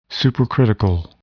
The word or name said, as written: supercritical